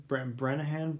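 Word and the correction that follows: Branagh: I don't know. – Brana. – Yeah.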